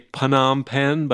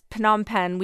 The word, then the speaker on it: Phnom Penh: Some people say Phnom Penh, but I think the standard pronunciation is silent PH. – Our pronunciation for the capital of Cambodia is Phnom Penh.